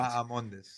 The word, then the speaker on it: Bahamondes: The H is silent. – Correct. – Bahamondes. – See, I said it more of like a Spanish way, though. You know, so that's what I get. You know, you injected the H, and that is where you faltered.